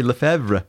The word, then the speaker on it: Lefebvre: Well, how do you pronounce it? – I say Lefebvre. – Well, that's right.